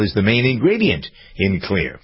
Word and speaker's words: Xlear: And then, using Navage, get the stuff out of my nose, and then keeping it clean with Clear. That's a prescription for fabulosity today. It really is. Clear, X-L-E-A-R, is pronounced clear.